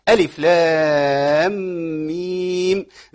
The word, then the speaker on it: ألف لام ميم: لأن يعني كثير من الناس بينطقها بشكل غير صحيح. لام، أخش على الغنة كده، مش أقول لام داخل خناقة، ما ينفعش. وبعدين ما ركبش غنة في اللام والميم. ألف لام ميم.